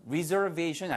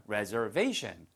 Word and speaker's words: reservation: 그래서 발음 reservation 아니고요. reserve가 동사지만 이렇게 예약이라고 할 때는 reservation처럼 발음이 에로 바뀝니다. reservation.